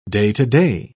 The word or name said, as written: day-to-day